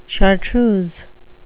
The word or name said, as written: chartreuse